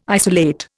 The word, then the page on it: isolate: Indian